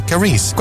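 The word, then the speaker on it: Karease: K-A-R-E-S-E, is it? – E-A-S-E. Yeah, okay. Carice. Grand, okay.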